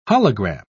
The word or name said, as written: hologram